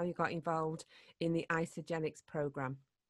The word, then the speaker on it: Isagenix: Now, have I pronounced that right? Because I've not heard of this before. So it's Isagenix. – Yeah, absolutely. Isagenix. Yep, you got it right.